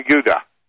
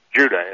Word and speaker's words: Judah: The 2 southern tribes were Benjamin and Judah, or Judah, as we pronounce now that we have a harder J sound in our language.